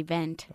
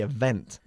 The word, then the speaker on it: event: No, the event. – Okay, enfatizando la segunda sílaba. The event.